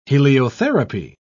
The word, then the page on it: heliotherapy: British English